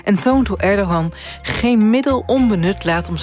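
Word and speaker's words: Erdoğan: Nou, eigenlijk precies ertussenin. Die G die spreek je eigenlijk niet uit. Dat zijn G met een dakje erop. Dus het is Erdogan. – Erdogan.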